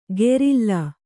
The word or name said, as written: ಗೆರಿಲ್ಲಾ